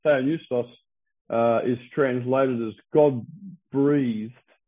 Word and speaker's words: Theopneustos: Theopneustos is how you actually pronounce it. It's written down there for you. T-H-E-O. English people generally say Theo. It's Theo. Theopneustos.